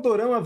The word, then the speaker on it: dorama: O termo dorama vem da pronúncia japonesa da palavra drama.